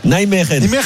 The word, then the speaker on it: Nijmegen: Nijmegen. – Nijmegen. Naïmeren.